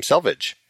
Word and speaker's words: Selvig: And I think that's how you pronounce his last name, Selvig.